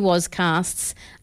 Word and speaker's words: Wozcast: Many, many tangent cities, many, many Diz Down Unders, a few Wozcasts. I did say that right. I was like, Wozcast. Yep, that's correct.